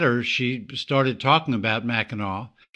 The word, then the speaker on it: Mackinac: You headed up to Mackinac Island, which I try to call Mackinac Island all the time because of the way it's spelled.